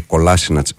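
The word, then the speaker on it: Kolašinac: πούμε, με πάρα πολύ μεγάλη συμπάθεια στο φίλο που λέει: γνωρίζω τη γλώσσα και σας διαβεβαιώνω ότι λέγεται Κολάσινατς, μη στεναχωριέστε.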